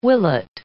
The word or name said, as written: willet